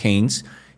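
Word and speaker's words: Hanes: Eh, yo probé varios de varias marcas y el que finalmente me gustó fue el Heinz, el, que se escribe Anes con H, Haynes, y usted busca ahí Haynes Hammock, o sea, hamaca eh, interior, y lo puede buscar.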